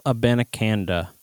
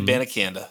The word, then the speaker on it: Abanikanda: Well, now we are going to test the size narrative thoughts on Devin Achane or Israel Abanikanda. I don't know if I said that right. I did my absolute best. – You did great. Izzy Abanikanda.